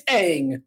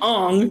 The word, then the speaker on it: Aang: And that's wrong because in the cartoon, it's Aang. So I don't know what they were thinking, but we'll call him Ong because that's what he's called in this movie. But that's not the pronunciation that's correct.